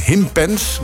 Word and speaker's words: Himpens: Het is heel ander weer hier in Himpens. Want zo moet je het eigenlijk uitspreken daar zuidelijk van Leeuwarden.